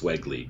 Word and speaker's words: Wegley: All right, and there you have John, and I believe it's Wegley, because I think he said it rhymes with Eggly.